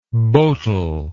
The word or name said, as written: boutel